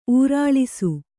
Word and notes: ಊರಾಳಿಸು: ūrāḷisu

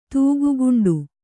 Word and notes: ತೂಗುಗುಂಡು: tūgu guṇḍu

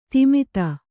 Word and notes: ತಿಮಿತ: timita

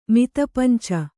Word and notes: ಮಿತಪಂಚ: mita panca

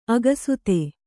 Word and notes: ಅಕ್ಷತ: kannada; akṣata

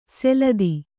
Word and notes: ಸೆಲದಿ: seladi

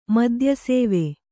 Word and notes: ಮದ್ಯಸೇವೆ: Kannada

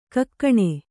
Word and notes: ಕೈಗೇಣ್: kaigēṇ

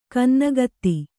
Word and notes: ಗನ್ನಗಾತಿ: gannagāti

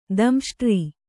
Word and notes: ದಂಷ್ಟ್ರ: damṣṭra

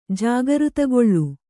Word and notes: ಜಾಗೃತಗೊಳ್ಳು: jāgřtagoḷḷu

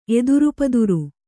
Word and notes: ಎದುರುಪದುರು: Kannada

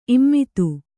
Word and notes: ಇಮ್ಮತ್ತಿ: Kannada

